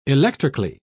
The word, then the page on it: electrically: British English